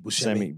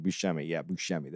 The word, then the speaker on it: Buscemi: Well, I like all the Buscemi, am I saying it right? – Steve Buscemi. – Buscemi. That's what I need the S there.